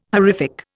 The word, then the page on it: horrific: Indian